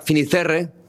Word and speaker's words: Fisterra: Entonces, llegó a Finisterre. ¿Se dice así? – Fisterra. – Es como se pone los gallegos también, en fin.